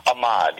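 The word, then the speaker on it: Amad: So we'll get, I don't know if I'm pronouncing this right, but it's Ahmad or Ahmad? – Ahmad, you're okay.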